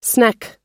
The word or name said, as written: sneck